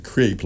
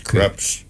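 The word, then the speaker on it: crêpes: French, I used to pronounce it crepe, like crepe paper, but crepes.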